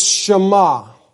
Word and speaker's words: Shema: And the name Simeon comes from a Hebrew word that you would pronounce Shema.